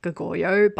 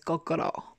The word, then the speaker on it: Goguryeo: So in English, it looks like you would say Goguryeo, but in the drama, everyone says Gogoro.